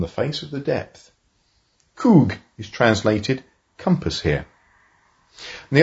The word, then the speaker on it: chuwh: Coug. C-H-U-W-H. Pronounced Coug.